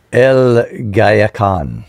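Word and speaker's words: Guayacán: How do you say that last word? – El Gayacan. – Gayacan.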